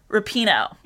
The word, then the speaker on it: Rapinoe: Megan Rapinoe.